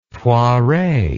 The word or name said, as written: Poiret